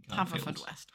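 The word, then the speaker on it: Haverfordwest: Haverford West. – Haverford West. – Right. – Apparently it's pronounced Hereford for those who live there.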